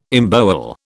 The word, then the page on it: embowel: British English